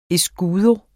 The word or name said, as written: escudo